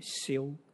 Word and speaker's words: Seow: 他的 last name 叫 Show，应该念 Siu，你知道那个潮州人啊，萧怎么念？Siu，S-E-O-W，他的 last name 怎么念？Siu 对吗？